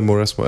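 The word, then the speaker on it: Mauresmo: Hur uttalar man hennes namn? Är det... Moresmo, va? Ja, det är Moresmo.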